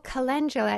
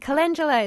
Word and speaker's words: calendula: Oh, calendula. It's CALENDULA. And calendula is the name, Latin name for marigold.